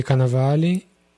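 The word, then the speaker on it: Cannavale: Bob Canivale? Não, como é que fala? – Bob Canavale Tá Nicole tá Kidman.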